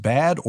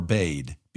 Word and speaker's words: bade: You have to tell me, is it pronounced bad or bade? B-A-D-E.